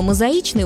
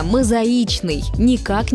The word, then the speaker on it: мозаичный: Будем произносить его правильно – «мозаичный», никак не «мозаичный».